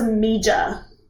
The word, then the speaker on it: Meager: Have I pronounced that correctly? – Yes, that is exactly right. It is confusing, but it is Meager.